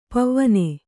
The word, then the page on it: ಪಾವನೆ: pāvane